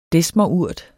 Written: desmerurt

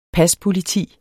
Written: paspoliti

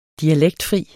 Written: dialektfri

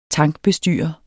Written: tankbestyrer

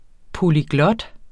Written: polyglot

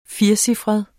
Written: firecifret

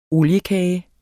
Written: oliekage

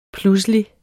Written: pludselig

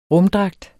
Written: rumdragt

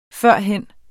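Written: FØRHEN

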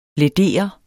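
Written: læder

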